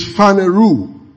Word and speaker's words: phaneroo: P-H-A-N-E. R-O-S-I-S. The verb of it is phaneroo. P-H-A-N-E-R-O-O. Now, pay attention.